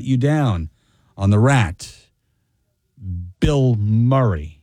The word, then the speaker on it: Bilmuri: You see, it's pronounced Bill Murray, but it's spelled B-I-L-M-U-R-I, like Bill Murray. But trust me when I tell you, it is Bill Murray, but not the Bill Murray.